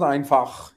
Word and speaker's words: Einfach: We're totally pronouncing it wrong too. I asked Gwen about this. I was like, my friend Sam and I live by the life philosophy of Einfach, and she's like, what? I guess it's pronounced like Einfach. – It's pronounced Einfach, yes.